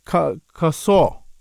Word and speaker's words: Kasoar: Doctor, before we carry on, your surname, is it pronounced Kasoar? – Yes, that's correct. – Okay, yep. Kasoar.